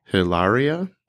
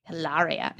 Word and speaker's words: Hilaria: It's spelled H A L A R I A. How do you pronounce that? – Hilaria. – Hilaria. It's like saying hilarious, except Hilaria.